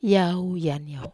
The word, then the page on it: aniau: Wamesa